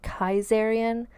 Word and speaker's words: Caesarion: The son was named Caesarion, or potentially it's pronounced Kaisarian, but basically it's a Greek name that means basically Caesar Jr. And she told everybody that the father of this child was Julius Caesar.